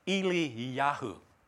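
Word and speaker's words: Eliyahu (אליהו): Now, interestingly, Elijah's name in Hebrew is actually pronounced like this, Eliyahu. Okay, that's how you say it in Hebrew.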